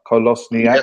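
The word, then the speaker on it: Kolašinac: We've got one, which is a brilliant piece of business, in my opinion, in Kolosniak. I think I've pronounced that right. Kolosniak. Yep.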